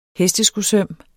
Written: hesteskosøm

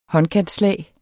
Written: håndkantslag

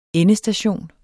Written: ENDESTATIONER

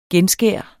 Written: genskær

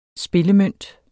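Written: SPILLEMØNT

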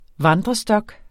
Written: vandrestok

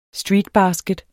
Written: STREETBASKET

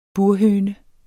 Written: burhøne

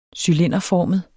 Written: cylinderformet

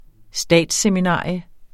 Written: statsseminarium

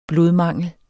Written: blodmangel

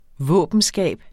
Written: våbenskab

